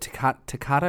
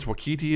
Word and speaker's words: Waititi: Is it Takata or Takita? I feel bad. Because I think it's Waititi is his last name.